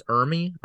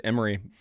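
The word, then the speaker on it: Ermey: I think it's Ermy, Arlie Ermy. – Oh, is that how you say it? I've always said Emery. But no, you're right.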